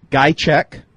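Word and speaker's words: Ghiaciuc: Eric Gajcek. That's how it's pronounced. It is not spelled anything like that, but it's Eric Gajcek, formerly of the Chargers, but had the highlights of his career in 2008.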